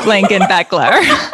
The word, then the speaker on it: Blakenbecler: Blakenbecler.